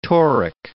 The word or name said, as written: toric